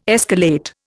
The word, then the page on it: escalate: Indian